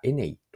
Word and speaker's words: ENEY: 今回ご紹介するのは、松屋銀座が新たに宝石ブランドを立ち上げたという話で、ブランド名はエネイと言います。エネイはアルファベットで ENEY と書いて ENEY と読みます。